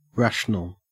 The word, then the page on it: rational: Australian English